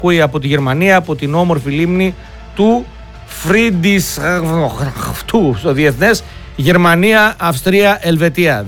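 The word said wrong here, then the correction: Friedrichshafen: Δεν διαβάζεται αυτό, ρε φίλε. Φρίντριχσάφεν.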